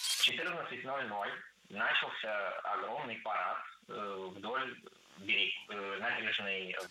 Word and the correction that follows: начался: Начался неверно. Правильно, начался. Ударение в этом слове падает на последний слог, поближе к возвратной части «ся».